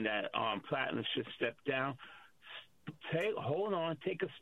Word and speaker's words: Platner: All this talk about Glenn Platner, I might mispronounce his last name. – Platner. – Platner. I'm so sorry.